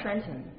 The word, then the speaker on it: Trenton: Trenton. – As close to this is impossible for a foreigner to say this word because it is a quasi two syllable word.